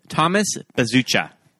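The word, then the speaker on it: Bezucha: Thomas Bezuka.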